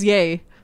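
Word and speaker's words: Ye: I really hate it's pronounced yay.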